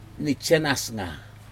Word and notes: nijhen asga: Aneityum

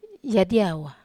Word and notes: adiava: Wamesa

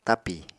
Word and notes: tapi: Apatani

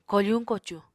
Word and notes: kofuñ-kocuñ: Apatani

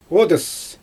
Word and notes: odys: Xyzyl

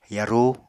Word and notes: yarú: apatani

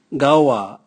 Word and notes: gaua: Raga

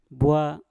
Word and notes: bwa: Mpur

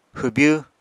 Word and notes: hwbyw: apatani

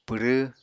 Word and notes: pwrẃ: Apatani

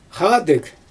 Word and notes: xaadɯg: Xyzyl

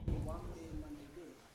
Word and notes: wande mandebe: Yawa

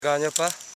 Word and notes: ganyapa: ga.nya.pa

